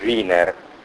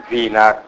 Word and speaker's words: Wiener: Allora, Wiener non c'è la lavagna, si scrive alla tedesca, si pronuncerebbe Wiener, perché si scrive W-I-E-N-E-R. Norbert, io l'ho sempre pronunciato alla tedesca, ma insomma probabilmente l'ho pronunciato all'inglese, perché lui era già trasferito. Quindi Wiener alla tedesca o Wiener all'americana, Norbert Wiener.